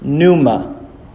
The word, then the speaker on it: pneuma: Okay, it's one syllable. Pneuma. Maybe two. Pneuma. Two.